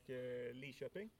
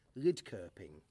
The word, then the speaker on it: Linköping: I'm thinking of moving here once I learn how to pronounce the name, because I think it should be pronounced Lidköping, and everyone tells me that that's wrong, and I'm insistent I'm right.